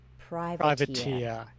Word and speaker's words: privateer: I mispronounced that, sorry. I thought it was a spelling thing, but that's he said he's a dashing Elizabethan privateer. Yes. – I went pirateer. I thought you're a pirate.